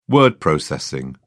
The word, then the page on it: word processing: British English